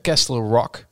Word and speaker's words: Castle Rock: Erik Baan die heeft al voor de tweede keer gereageerd van, bespreek nou eens een keer Castle Rock. – Ja, maar die T die spreek je niet uit.